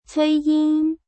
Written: cuiying

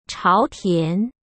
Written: chaotian

